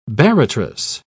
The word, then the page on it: barratrous: British English